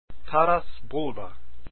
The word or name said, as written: Taras Bulba